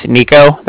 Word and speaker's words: Niko: Like I said, my name is Nico. That's spelled N-I-K-O. – Okay. – And then the Bank of Cardiff is spelled C-A-R-D-I-F-F, as in Frank.